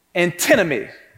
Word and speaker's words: antinomy: It's called antinomy.